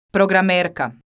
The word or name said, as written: programerka